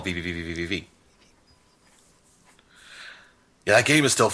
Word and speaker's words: VVVVVV: VVVVVVVV. – I didn't know you pronounced the full V. I thought you just went V. – No, it's V, V, V, V, V, V, V.